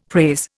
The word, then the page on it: praise: Indian